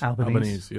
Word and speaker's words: Albanese: How did we say that? Albanese? – Albanese, yes.